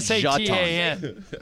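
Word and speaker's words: Šatan: They pronounce it Jatan. They pronounce it Jatan. – Just to get it nowhere near Satan.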